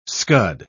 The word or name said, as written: scud